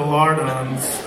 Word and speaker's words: lardons: Remove the bacon rind and cut into lardons. It rhymes with hard-ons, but that's easy.